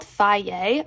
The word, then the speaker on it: Fage: It's called Faye, okay? It's spelled F-A-G-E, and they sell it at like Stop and Shop, ShopRite, and those are the two that I know.